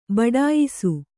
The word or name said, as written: ಬಡಾಯಿಸು